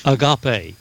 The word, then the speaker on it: agape: In our English Bible, we have a word, love, that's translated from the original meaning or pronounced agape.